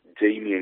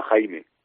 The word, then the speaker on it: Jamie: Pues mira, te cuento, yo me llamo Ángel Jamie, que es Jamie en inglés, mi madre es americana, entonces me pusieron ese nombre por mi tía. Yo tenía antes puesto Jamie, como se escribe en inglés, pero la gente me llamaba Jaime, porque lo leían, no sé, en España, se lee de cierta manera, y la gente tendía a confundirlo. Entonces, ¿qué dije?